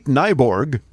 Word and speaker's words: Nyborg: Tinder CEO Renate Nyborg, rhymes with cyborg, is out.